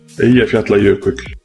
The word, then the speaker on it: Eyjafjallajökull: Eu tentaria algo como Eyjafjallajökull. Eyjafjallajökull. Eu tentaria isso. Vamos ver a pronúncia real. – Eyjafjallajökull. – Tem um C no final.